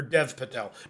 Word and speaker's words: Dev Patel: Dave Patel. Or Dev Patel. Not Dave Patel.